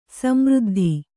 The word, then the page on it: ಸಮೃದ್ಧಿ: Kannada; samřddhi